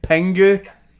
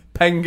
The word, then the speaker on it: Pingu: Pingu. It's a nightmare to say my accent. Pingu. – Right, can you spell it? – Yeah, P. – Yeah. – I. NGU, like the cartoon Pingu.